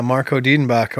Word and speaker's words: Marco Dedenbach: Did I say that correctly? – Yeah, Marco Diedenbach, or the Master the Mountain.